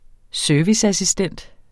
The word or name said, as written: serviceassistent